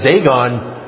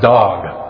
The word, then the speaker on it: dag: It was a great dag. or it's actually pronounced dog.